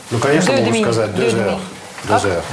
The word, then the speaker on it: Deux heures: При соединении, при связке читается как z. Deux heures.